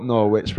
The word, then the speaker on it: Norwich: It's spelt Norwich, but it's pronounced Norwich.